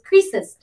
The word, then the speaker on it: Croesus: We talked a little bit about King Croesus uh, or Croesus, uh, the way that it's spelled, but the way it is pronounced is Croesus.